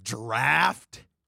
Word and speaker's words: draught: So it's spelled DRAUGHT, and you think that when said sounds like draft?